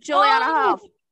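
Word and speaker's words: Julianne Hough: Juliana Hough.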